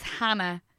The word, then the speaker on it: Hannah: My name is Hannah. I'm the new host. I replaced Cian. – What's your name? – Is Hannah.